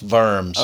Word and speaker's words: Worms: The Germans pronounce it very. It's spelt worms, which always amused me, but it's pronounced worms.